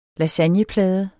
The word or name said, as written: lasagneplade